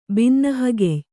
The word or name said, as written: ಬಿನ್ನಹಗೆಯ್